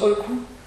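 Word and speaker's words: oeku: Ça s'écrit ECU, mais ça se prononce OECU à l'allemande, on va dire.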